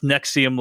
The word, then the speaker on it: NXIVM: And it's about this thing called Nexium, which is spelled NXIVM, but it's pronounced Nexium like the antacid drug.